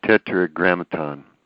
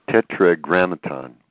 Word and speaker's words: Tetragrammaton: to imprint his name or the tetramerigan. I know I'm not pronouncing that right. – Tetragrammaton. – Pardon me? – Tetragrammaton.